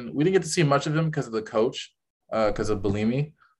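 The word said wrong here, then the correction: Bielema: Or am I saying that right? Bulimi or Bulima. – Bulima. I knew I was messing up a little bit, but Bulima.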